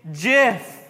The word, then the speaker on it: GIF: Actually, it's JIF. It's JIF, all right, with a J.